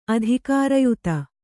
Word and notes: ಅಧಿಕಾರಯುತೆ: adhikārayute; Kannada

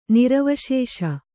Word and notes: ನಿರ್ವಿಶೇಷ: nirviśeṣa

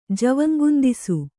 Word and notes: ಜವಂಗುಂದಿಸು: Kannada

